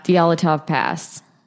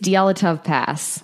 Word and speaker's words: Dyatlov Pass: Am I saying that right? Probably not. – Dyatlov Pass. – Yeah. – Dyatlov Pass. – Dyatlov. Okay. It says D-Y-A-T-L-O-V. – Desi? It's Dyatlov Pass.